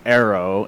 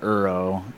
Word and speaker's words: Ero: I mean, it's just E-R-O, but everybody calls me Arrow instead of Uro, and that's just how it is.